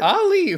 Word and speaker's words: Ali: Hey, Lee, why are you like this? – I'm not sure it comes up later on, but the A in front of it is just pronounced ah.